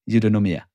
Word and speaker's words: Eudaimonia: There's a question there that is for sure looming that I'm hearing, you know, in your introduction already with Endomia and Oikos. Like it's already there, like I said it wrong. What is it? Eudaimonia.